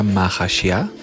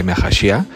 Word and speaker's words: Mahashia: Entonces, curación, muy interesante este nombre, es el número 5, curación, se pronuncia Mahashia, en español se pronuncia Mahashia, o las letras, reitero, son Mem, Hei y Shin, es curación.